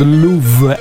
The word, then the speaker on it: Luv: Emanuela Gubinelli, questo è il vero nome di Talisa, che nel 1994 uscì con il pezzo I Found Love, L-U-V, poi si pronuncia love ovviamente.